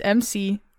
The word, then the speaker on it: McEnany: Trump campaign spokesperson Kayleigh McEnany. Is that how you say her name? McEnany? I don't know. It's MC. – I'm not looking at it. I don't know. – It's MCENANY. McEnany.